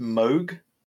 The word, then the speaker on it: Moog: I think it's pronounced Moog, as an aside. It's a brand of synthesizer.